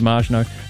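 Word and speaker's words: Maginot: To those smart Alex who had the arrogance and ignorance to criticise your pronunciation of Marginot, they're utterly wrong and you are correct. The A in French is pronounced as we pronounce the indefinite article. In other words, as you pronounced it, there is no short A as an apple in French pronunciation.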